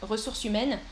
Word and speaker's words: ressources humaines: Je travaille aux ressources humaines. Pas de liaison. Pas de liaison ici. Euh, alors, ah oui, parce que tu répondais à la question de Nayera. Comment prononcer ressources humaines Ouais, ressources humaines.